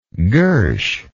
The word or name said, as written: gursh